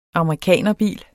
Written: amerikanerbil

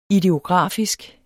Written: ideografisk

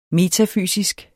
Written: metafysisk

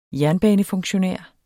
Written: jernbanefunktionær

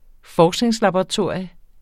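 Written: forskningslaboratorie

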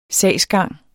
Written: sagsgang

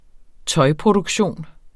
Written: tøjproduktion

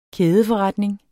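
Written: kædeforretning

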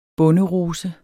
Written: bonderose

